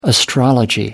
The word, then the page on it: astrology: American English